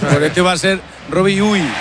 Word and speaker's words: Robbie Ure: A ver, el club pasó incluso un audio, lo ha pasado. Y en concreto sería Robbie Ure.